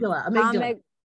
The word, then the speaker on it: amygdala: Amygdala, right? – Yeah, I think it's Amygdala.